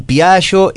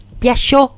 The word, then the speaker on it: Piaggio: Porque hay varias formas, ¿es Piaggio o es Piaggio, Piaggio, así, con la ¿Cómo? ¿Tus abuelos qué decían? – Piaggio, Piaggio, así medio paisano nomás, ¿viste? – Piaggio. – Sin mucha pronunciación así media italiana, sino que Piaggio.